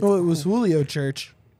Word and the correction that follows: Furch: Julio Furch.